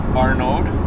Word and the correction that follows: Arnaud: A R N A U D. – Okay, Arnaud, Arnaud.